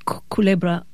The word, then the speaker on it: Culebra: Tell us about what you saw in, is it Culebra? Is that how you pronounce it? – Culebra.